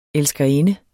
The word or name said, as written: elskerinde